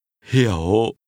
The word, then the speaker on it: hiểu: What other Vietnamese word sounds something like this one? The word understand. They sound similar, but understand is pronounced with a low, falling, rising tone. – Hiểu. – And shop is pronounced with a low, falling, broken tone.